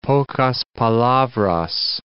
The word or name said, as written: pocas palabras